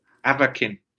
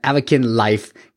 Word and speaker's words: Avakin: If you want to check out the app, it is Akavin. I feel like I'm saying it wrong, but Akavin Life, like Avatar, Kin. – Avakin. – Avakin Life. See, it's the emphasis that I'm putting it on too much. But Avakin Life, go check it out in the app stores.